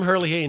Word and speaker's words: Herlihy: I always get his name wrong. Tim Herlihy.